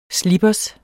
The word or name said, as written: SLIPPERSERNES